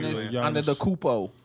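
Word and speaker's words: Giannis Antetokounmpo: Giannis. Antetokounmpo.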